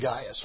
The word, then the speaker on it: Gaius: I suppose in this day and age I should pronounce it Gaius, right? All right, so his name is Gaius.